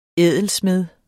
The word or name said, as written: ædelsmed